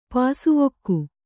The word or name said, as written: ಪಾಸುವೊಕ್ಕು